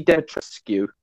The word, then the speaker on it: Dimitrescu: You got Lady Dimitrescu. Did I pronounce that right? – Yes, you did it.